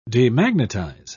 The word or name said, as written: demagnetize